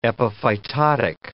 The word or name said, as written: epiphytotic